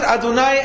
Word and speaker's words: Adonai: You pronounce it Adonai any way you look at it. If it's Adoni times three, it's Adonai.